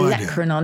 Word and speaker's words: olecranon: First of my three is olecranon. Do you know what this is, Gyles? – Your no olecranon.